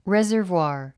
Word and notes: reservoir: rézə r vwὰː r; レザヴワー

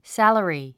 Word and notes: salary: sǽləri; サァラリー

